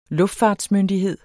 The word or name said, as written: luftfartsmyndighed